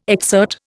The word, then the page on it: exsert: Indian